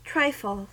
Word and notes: trifle: American English

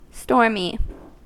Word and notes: stormy: American English

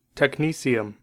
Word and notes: technetium: American English

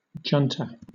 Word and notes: junta: British English

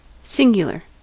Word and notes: singular: American English

